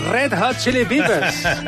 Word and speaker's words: Red Hot Chili Peppers: Los Red Hot Chili Peppers. Así es como se pronuncia.